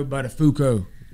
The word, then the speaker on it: Buttafuoco: Yeah, Joey's wife, Mary Jo Buttafuoco.